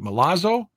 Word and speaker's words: Milazzo: It's spelled M-I-L-A-Z-Z-O. Is it Milazzo? – I guess, yeah.